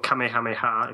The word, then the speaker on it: Kamehameha: But Cell actually comes with a Kamehameha, or Kamehameha, if I'm going to say it properly, key attack wave thing.